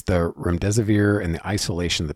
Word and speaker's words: Remdesivir: Protocol was to give COVID-19 patients, how do you pronounce this? Remdesivir? Is that what it is? – Remdesivir.